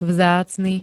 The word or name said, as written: vzácny